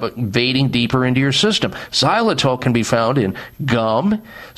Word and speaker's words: xylitol: The best way to make sure you keep your mouth clean is by incorporating something called xylitol into your life. Xylitol. If you don't know about what xylitol is, you should. Xylitol is spelled with an X. X-Y-L-I-T-O-L. Xylitol.